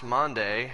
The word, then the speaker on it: Maundy: But as I did a little research, I actually found out that I don't know everything, that it really is pronounced Monday, and that's because it's a Latin word, and the Latin word means a mandate or a command.